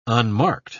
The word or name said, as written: unmarked